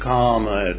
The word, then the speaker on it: kama: K-A-M-A. You all mispronounce, not all, I beg your pardon, but a good many mispronounce karma, K-A-R-M-A, by calling it kama. It isn't kama at all. It's karma, but this is kama, desire, passion.